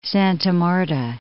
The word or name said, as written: Santa Marta